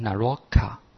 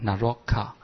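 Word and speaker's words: Naraka: 那原文它是这样念的齁、哦、就是地狱道齁、哦、就是乃若家啊，它的梵文应该是念成 Naroka 啊 ,Naroka。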